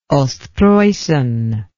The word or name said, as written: ostpreussen